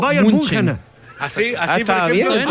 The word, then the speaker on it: Bayern München: ¿nos regalaría usted cuál es la correcta pronunciación del Bayern Múnich? Hay varias, pero pues eh, FC, Bayern München.